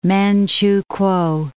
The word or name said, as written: Manchukuo